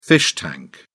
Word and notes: fish tank: British English

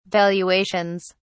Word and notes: valuations: British English

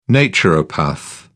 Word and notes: naturopath: British English

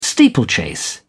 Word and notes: steeplechase: British English

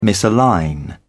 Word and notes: misalign: British English

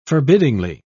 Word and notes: forbiddingly: British English

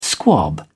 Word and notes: squab: British English

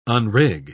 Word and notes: unrig: British English

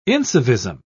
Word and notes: incivism: British English

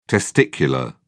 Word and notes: testicular: British English

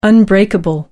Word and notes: unbreakable: American English

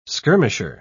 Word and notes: skirmisher: British English

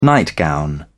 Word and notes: nightgown: British English

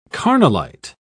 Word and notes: carnallite: British English

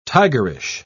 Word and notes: tigerish: British English

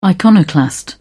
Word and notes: iconoclast: British English

